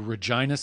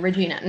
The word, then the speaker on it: Regina: Regina. No, that's not how you say it. Why don't we add some flavor? Regina.